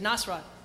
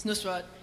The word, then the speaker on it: Nusrat: Because I have a friend named Penny, and I played her the thing, and she said Nasrat. – Yeah. – It's Nusrat, and he's my Elvis.